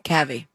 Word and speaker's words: Calvi: Doral police told people that the teacher, Harry Calvi, all right, I hope I'm saying that wrong, H-E-I-R-Y-C-A-L-V-I, Harry Calvi? – Cavi.